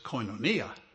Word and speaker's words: koinonia: The word koinonia, as a very kind Greek lady pointed out to me when I was, for the past 49 years, pronounced it incorrectly. Koinonia that's what that is.